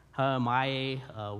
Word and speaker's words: hallomai: It would transliterate maybe H-A-L-L-O-M-A-I, Hamai, we'd say, probably pronounce it.